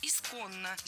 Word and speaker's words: исконно: Второе похожее слово – «исконно». Здесь уже нужны две «н», а ударение ставим на второй слог – «исконно».